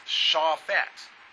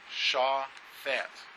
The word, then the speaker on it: Shaphat: Now, this is a Hebrew word, and it is pronounced Shaphat. Shaphat.